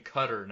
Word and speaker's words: Qatar: Qatar, the country, not Qatar. – No, actually, they prefer the pronunciation Qatar now.